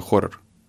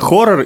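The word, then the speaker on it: Ho99o9: называется «Хоррор». Она пишется H-O 9-9.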